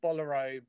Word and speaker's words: Bolero: She also educated Rob and I with the whole bolero. Turns out bolero is a dance. Bolero is the correct way of pronouncing it.